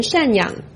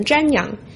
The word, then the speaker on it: 赡养: 今天为了纪念五四运动 100周年，习近平特意在 4月30日提前致辞，结果把老人赡养错读成老人瞻仰，还有人指出他把迸发读成了并发。